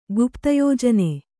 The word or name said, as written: ಗುಪ್ತಯೋಜನೆ